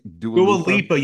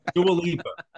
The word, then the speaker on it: Dua Lipa: Dua Lipa. Okay, am I saying her name right? – Dua Lipa, you fucking mook. Dua Lipa.